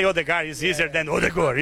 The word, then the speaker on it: Ødegaard: Ødegaard.